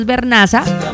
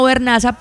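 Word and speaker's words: Vernaza: Mi apellido es Bernaza, se escribe con V y con Z. Entonces ese mismo Bernaza, pero en diminutivo.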